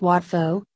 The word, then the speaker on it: Wat Pho: Commonly known as Wat Pho, pronounced poor.